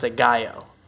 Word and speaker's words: Aguayo: So you are sticking by your ranking of Aguayo. – I think it's pronounced Aguayo. – Yeah, that sounds right.